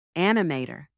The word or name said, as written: animator